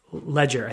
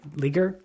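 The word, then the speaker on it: Legere: Ledger, I think. Leger? I don't know. I forget how to pronounce his Leger. – Name. Leger. Yeah. Yeah, Leger. I don't know.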